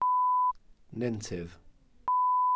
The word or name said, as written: nentydd